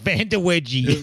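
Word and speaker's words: Vandeweghe: What'd you call him? Vanderwedgie? – Vanderwedgie. How the fuck would you say his name? – That's perfect. Vanderwedgie.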